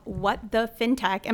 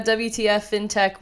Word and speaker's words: What the FinTech: Today, we are talking with creator and founder of What the FinTech. Am I pronouncing it right? – Yes. What yes, pronouncing it right. A lot of people think it's a WTF FinTech, which is fine.